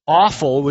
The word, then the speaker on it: offal: Now, the word that is given in my version of the NIV, which is awful, which is O-F-F-A-L, and that is how it's pronounced, and believe me, it was awful.